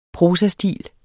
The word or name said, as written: prosastil